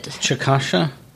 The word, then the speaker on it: Chickasha: Except they do pronounce it Chickasha? – Chickasha. – Chickasha. – Chickasha is the Chickasaw way of saying Chickasha, a town we lived in for 4 years. – Yes. – But they say Chickasha, and it grates, but I also find it delightful.